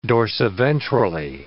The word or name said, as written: dorsiventrally